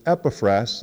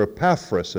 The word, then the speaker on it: Epaphras: His name was Epaphras, that's one pronunciation, or Epaphras if you prefer, which is probably more of an American pronunciation.